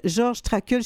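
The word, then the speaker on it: Georg Trakl: Il s'appelle Georges Traquel. Je ne sais même pas si je le prononce correctement. – Oui, oui, absolument. Oui. Tout à fait.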